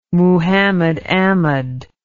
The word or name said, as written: Muhammad Ahmed